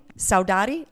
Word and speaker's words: saudade: I think I'm pronouncing it right, but tell me if I'm not. Saudade? Saudade? Saudade? I'm pronouncing it like with a Hispanic accent. I think that sounds... – No, that's perfect, yeah.